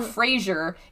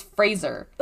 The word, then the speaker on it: Fraser: It's not Fraser. It's Fraser. – Ugh. – Like Razor. But with an F.